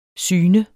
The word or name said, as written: syn